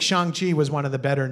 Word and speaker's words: Shang-Chi: We skipped The Incredible Hulk, Iron Man 3, Thor Dark World, Guardians of the Galaxy, Ant-Man and the Wasp, Black Widow, Shang-Chi or Song-Chi, I don't know how to pronounce it. – Shang-Chi.